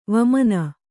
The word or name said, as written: ವಾಮನೆ